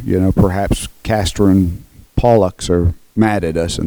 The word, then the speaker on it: Castor and Pollux: Castor and Pollux, I believe, is how it's pronounced.